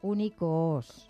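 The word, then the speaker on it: Unicoos: Lo he dicho mal, porque he alargado la U y es la O. Es Únicos.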